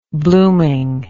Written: bloomingness

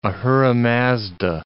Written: Ahura Mazda

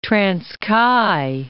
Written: Transkei

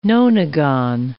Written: nonagon

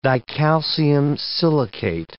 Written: dicalcium silicate

